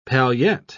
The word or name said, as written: paillette